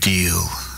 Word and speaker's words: Diehl: Crooked Deal, there, the flood. Crooked Deal is spelt D-I-E-H-L, deal.